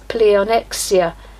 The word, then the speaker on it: pleonexia: The word in Greek is pleonexia, meaning to covet, desire, want, crave, yearn, hanker after, and it is always used in a negative sense. It's spelt P L E O N E X I A, pleonexia.